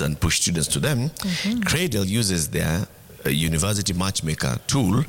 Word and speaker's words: Craydel: And I'd like us to, you know, spell the word cradle, pronounced as cradle, spelled C-R-A-Y-D-E-L, cradle.